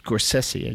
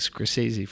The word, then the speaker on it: Scorsese: But apparently, Mr. Scorsese, it's pronounced Scorsese, I just learned. I've been saying Scorsese for years.